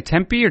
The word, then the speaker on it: Tempe: What do you say, Tempe or Tempe? – The accent's on the first syllable. And they'll correct you here if you accent the second syllable.